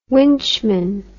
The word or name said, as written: winchman